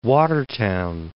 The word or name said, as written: Watertown